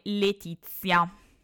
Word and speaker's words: Laetitia: Il suono TI, T viene invece pronunciato con la Z, quindi se abbiamo la scritta Laetitia, lo leggeremo secondo la pronuncia tradizionale Letizia.